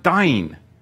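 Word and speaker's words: dine (דין): The interesting thing when you look at this in the Hebrew, strive is the Hebrew word dine and it's spelt Daleth Yod Nun Sofit, as in Dan, as in meaning to judge.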